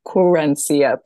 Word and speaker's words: Querencia: yeah definitely um so i had a little micro memoir come out with Querencia Corent I can never pronounce the name of my publisher. It's Querencia Press.